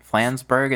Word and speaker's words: Flansburgh: Flansburgh, is that how you say it? – I think so, yeah.